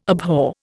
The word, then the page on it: abhor: Indian